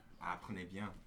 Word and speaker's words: Apprenez bien: Okay, how are you going to pronounce that? – Apprenez bien. Ah. – Apprenez bien. Uh, like learn B1? – Yeah.